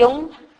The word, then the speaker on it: Jung: Y Jung, se dice así, Carl Gustav Jung, él habló mucho de las mandalas.